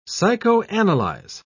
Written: psychoanalyze

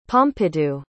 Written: pompidou

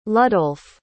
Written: ludolf

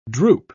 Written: droop

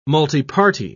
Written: multiparty

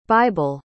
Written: bible

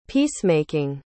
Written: peace-making

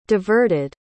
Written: diverted

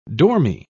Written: dormy

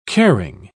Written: caring

